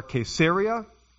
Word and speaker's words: Caesarea: Paul was in Caesarea, or Caesarea, as we learned last week to pronounce it.